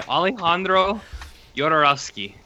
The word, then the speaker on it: Alejandro Jodorowsky: Holy Mountain by Alejandro Jodorowsky. That is the correct pronunciation.